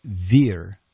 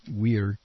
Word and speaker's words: vir: The Latin word for an actual male man would be vir or weir. Yeah, that's the Vulgate, the way we were taught to pronounce it, weir.